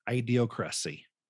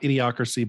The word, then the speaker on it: ideocracy: or an ideocracy. And yeah, that's a real word. I pronounced it wrong. It's not idiocracy, but it's very similar to that.